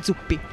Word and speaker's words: Zuppi: Zuppi.